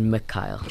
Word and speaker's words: Mykyle: I got a lot of confusion during school with the Mykyle. – Okay. – Because it's pronounced Mykyle.